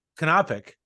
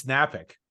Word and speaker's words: Knapik: Even in my own family, which long time ago came from Poland, they would say my name as Knapik. And here it's Napik. Nobody pronounces the K.